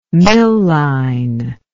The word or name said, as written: milline